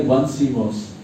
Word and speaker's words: Onesimus: In English, how do we pronounce it? It sounds like one Simos, O-N-E.